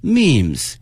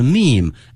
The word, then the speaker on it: meme: Also, these memes 出现了表情包，这个英文翻译呢是 meme， M-E-M-E，但是呢后面这个 E 是不发音的啊，叫 meme，后面是一个复数形式 memes。